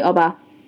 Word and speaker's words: Oba: So it lit the way to and fro the Oba's palace. I'm like, did I pronounce that correctly? Is it Oba for the king? – Oba Palace. Yeah. – Oba. Okay.